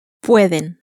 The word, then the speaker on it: pueden: Pueden. Pueden. – So, the first consonant, which is also the one next to last, is the one that receives the stress.